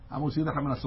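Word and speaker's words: hamotzi lechem: The two mems you have to pronounce them. Hamosi lechem and artist. But if you say it quickly, Hamosi lechem. So what happens? I figured out which letters was I swallowing. I was swallowing the mem of Hamosi lechem and artist.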